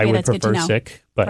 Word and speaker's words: Sikh: But you practice, as you say, Sikhism, S-I-K-H. Most of us see that word and we think a Sikh, but are we mispronouncing it? – Yeah, I mean, there's two pronunciations. The original in the Punjabi language is Sikh. – Okay. – So we say Sikhi as the name of our tradition. And in English, that would come out as Sikhism. Sikh is the colonial pronunciation. So when the British came to India, they started saying Sikh.